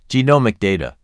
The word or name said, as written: Genomic data